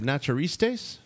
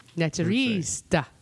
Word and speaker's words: naturiste: Naturista. – No, there's no ta.